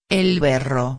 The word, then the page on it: el berro: espanhol